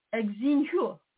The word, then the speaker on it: Xinhua: Xinhua.